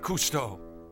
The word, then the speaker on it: Cousteau: Cousteau.